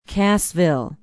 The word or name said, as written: cassville